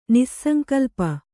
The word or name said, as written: ನಿಸ್ಸಂಕಲ್ಪೆ